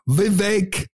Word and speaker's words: Vivek: Vivek rhymes with cake. That's what he said. I was calling him Vivek for months, but he said it's Vivek.